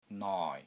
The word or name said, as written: NOI6